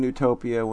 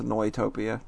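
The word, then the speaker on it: Neutopia: People keep saying Newtopia when it's pronounced Noitopia. Just saying. – Noitopia? – Yeah. – That sounds made up, but okay. – The EU... it's a German term! The EU is oi.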